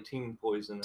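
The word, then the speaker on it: TeaMp0isoN: TM Poison. – It's actually Team Poison. – Team Poison. Well, it's the way it's spelled.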